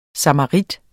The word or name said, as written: samarit